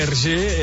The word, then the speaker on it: Hergé: Sí, sí, tant, del senyor Hergé, que per qui no sàpiga, Hergé és la... com es pronuncien les inicials del seu nom i el seu cognom, però invertits. O sigui, ell es deia George Remy, per tant, Hergé és R, la R, Remy, i G de Jocs.